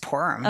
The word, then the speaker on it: Purim: Just Purim. – Okay. I think that's the Yiddish pronunciation. And then I think the Hebrew is like Purim, but I don't know.